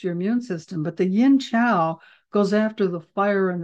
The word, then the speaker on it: Yin Qiao: Now, the formula that goes with Gan Mao Ling sometimes is Yin Qiao, and it can be spelled Q-I-A-O or C-H-I-A-O. Sometimes Qi is spelled Q-I and sometimes Qi is C-H-I, but it's Yin Qiao.